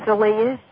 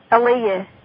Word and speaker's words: Alia: Her name was Aaliyah. – Elise? – Aaliyah. – I want to make sure I'm pronouncing it correctly. – A-L-I-A. – Oh, okay. Aaliyah. A-L-I-A.